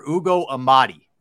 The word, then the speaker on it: Ugo Amadi: Arcega-Whiteside for Ugo Amadi. I believe I'm pronouncing that correctly. – Mm-hmm.